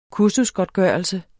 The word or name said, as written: kursusgodtgørelse